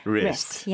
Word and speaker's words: wrist: W로 시작한다고 뭔가 강하게 W 소리 안 내도 돼요. – wrist 아닙니다. 그냥 wrist.